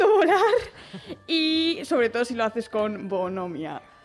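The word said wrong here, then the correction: bonhomía: Pues, se dice bonomía, que lleva acento en la I.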